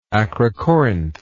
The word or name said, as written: acrocorinth